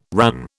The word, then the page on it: RAN: British English